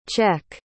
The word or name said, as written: czech